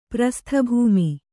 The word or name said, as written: ಪ್ರಸ್ಥಭೂಮಿ